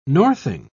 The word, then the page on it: northing: British English